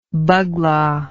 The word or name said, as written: baghla